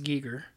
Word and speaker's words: Giger: I think it might be Geiger. – No, it's spelled Geiger, but... Like when you look it up, it's like got the funny. – It's well, yeah, it said that it's pronounced Giger.